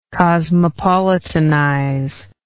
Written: cosmopolitanize

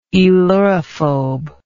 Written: aelurophobe

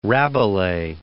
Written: Rabelais

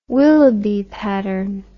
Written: Willoughby Patterne

